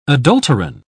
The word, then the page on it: adulterine: British English